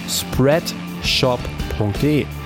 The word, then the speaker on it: spreadshop.de: Checkt das mal aus auf spreadshop.de. Das Ganze schreibt man S-P-R-E-A-D S-H-O-P. Also spreadshop.de.